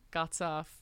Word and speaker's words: Guttah: Before that, you heard Gutter from Aliwa. And I say that, sounds like I'm saying it with a strange accent, but it's because it's spelled G-U-T-T-A-H.